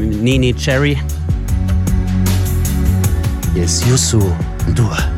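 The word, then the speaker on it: Youssou N'Dour: Willst du es mal probieren, Freddy? – Ja, Yusu Ndur. – Ja, gar nicht mal so schlecht.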